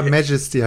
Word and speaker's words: Majesty: ganz andere Betonung. Ja, Majesty heißt das Ding.